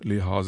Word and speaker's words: Lehár: Der Name Lehár wird in allen Staaten der Welt anders ausgesprochen. Um es gleich richtig zu stellen, die Betonung liegt nicht auf der ersten, sondern auf der zweiten Silbe, und das H muss natürlich zu hören sein.